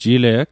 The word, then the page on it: jile'g: Mi'gmaq